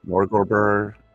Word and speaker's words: Norgorber: You'd recognize it as a book that someone that worships Nordorberg. Is that how it's pronounced? Norgorber. – I've been going with Norgorber.